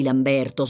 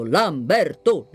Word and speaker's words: Lamberto: Poi bisognerebbe avvertire la signora Zanzi di non tenere così lunga la seconda sillaba di Lamberto smorzando la terza.